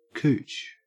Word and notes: cooch: Australian English

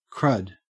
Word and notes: crud: Australian English

